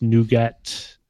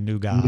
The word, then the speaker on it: nougat: I Amazon did that for all of you Australians and English and other people who say nougat. – Nougat. – Nougat. – What does that mean? Just why? Then why add a T? If you're just going to... – Well, there's plenty of words that you have a T that you don't pronounce.